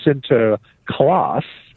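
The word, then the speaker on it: Sinterklaas: but he was pronounced something like Sint or Sinter Niklas. And you shorten Sinter Niklas to Sinter Klaas, and you are pretty close to what we recognize as Santa Claus.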